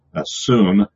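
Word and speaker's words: syn: It sounds a little more like S-O-O-N, soon. It would be a long U, kind of a soon sound. I guess it probably should put it soon, make it sound it out, but make a long U of it anyway. But it's soon.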